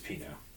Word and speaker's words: Pinaud: For the record, it is Pinaud. – Oh, this is Pinaud. Okay. – Pinaud. – I think the accent on the second syllable there.